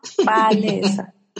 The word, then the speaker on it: Palesa: Because when you say, you almost say like there's an N in it. It almost sounds like there's an N. – N? Palesa. – Palesa. Okay, it's the way the L is pronounced.